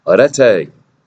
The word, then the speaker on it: arete: It's arete. A-R-E-T-E. Okay, he even tells you how to speak like an ancient Greek. Modern Greeks pronounce it differently, but the ancient Greeks pronounced it this way.